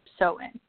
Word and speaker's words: Samhain: H-A-I-N, but it's pronounced Samhain.